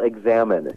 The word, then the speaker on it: Examen: It's called Examen.